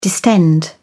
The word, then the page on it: distend: British English